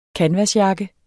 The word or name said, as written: kanvasjakke